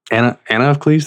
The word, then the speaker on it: Anna of Cleves: Anna of Cleves, did I say that right? – That's it. Mm-hmm.